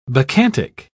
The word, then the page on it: bacchantic: British English